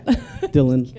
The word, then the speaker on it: Dylan: Yeah, Dylan, like Bob Dylan. Hi, I'm Dylan.